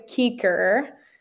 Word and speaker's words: Kueker: Okay, so my last name is Kueker. It's spelled K-U-E-K-E-R, which everyone always says Kueker.